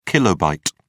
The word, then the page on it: kilobit: British English